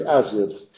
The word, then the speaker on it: azure: Pero aquí es Azud en la pronunciación de esta época.